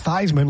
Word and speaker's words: Theismann: It's pronounced Theismann like Heisman. Make it and rhyme with Heisman.